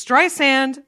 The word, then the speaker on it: Streisand: The thing she had a problem with was Streisand. Apparently Siri was saying Streisand and she didn't like that because according to her, it's Streisand like sand on a beach.